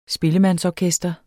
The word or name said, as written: spillemandsorkester